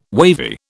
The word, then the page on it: wavy: British English